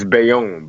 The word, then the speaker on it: Bayonne: but it's Bayonne. – It's Bayonne, Bayonne, yeah.